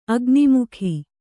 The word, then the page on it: ಅಗ್ನಿಮುಖ: agimukha